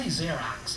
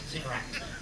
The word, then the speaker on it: Xerox: I say Xerox, it's American, it kind of rhymes with anthrax, Xerox.